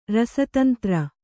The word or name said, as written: ರಸತಂತ್ರ